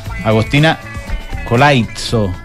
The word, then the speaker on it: Agostina Colaizzo: Sí, ayuda a tener los mejores invitados, como en este caso, Agostina Colaizzo. ¿Lo dije bien? – Perfecto.